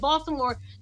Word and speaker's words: Baltimore: You say Baltimore. You can hear the T, but you say it like the natives. They say Baltimore. You don't even hear a T when they say it.